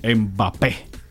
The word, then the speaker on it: Mbappé: Kylian Mbappé. Ahí. Con acento en la última E. – En acento en la E. – Sí, en la última. – No Mbappé, Mbappé como dice Soto.